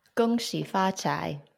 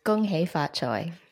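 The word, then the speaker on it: Gong Xi Fa Chai (恭喜发财): Gong Xi Fa Chai, or in Cantonese, Gong Hei Fa Choi.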